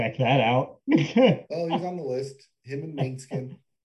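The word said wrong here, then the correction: Måneskin: Monoskin, I think is how it's pronounced. – Maybe I'm calling it Mainskin.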